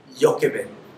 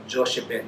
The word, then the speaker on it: Jochebed: It's not Jochebed, okay? But it should be pronounced as Jochebed.